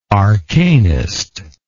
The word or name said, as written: arcanist